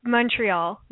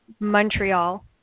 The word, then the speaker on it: Montreal: Well, it's just Montreal. It's not like on. It's just Montreal.